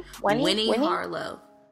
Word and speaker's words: Winnie Harlow: Weenie... Oh, am I saying her name? – Winnie Harlow.